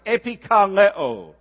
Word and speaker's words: epikaleo: It's a little Greek word we pronounce epikaleo. The word kaleo even sounds like the word call.